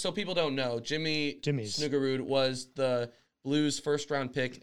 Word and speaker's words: Snuggerud: Is it Snuggerud? Is that how you say it? – Snuggerud.